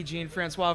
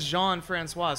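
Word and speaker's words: Jean-François: Like, he introduced himself as Ricky Jean-Francois, so then I was pronouncing it Ricky Jean-Francois for a while later, for a time after that. Then it was brought to my attention that it is Jean-Francois, so you just got to make sure you get the pronunciation down.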